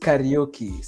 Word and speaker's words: Kariuki: It's pronounced Kariuki. So Kariuki.